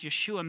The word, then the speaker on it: Yeshua: And the very name of Jesus in Hebrew, pronounced Yeshua, means salvation.